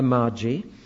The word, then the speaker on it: Magi: First, we'll talk about the Magi. I still think that's the right pronunciation.